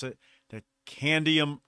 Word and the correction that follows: cadmium: how do you say that one? Does anybody remember? The cadmium? – I think it's cadmium.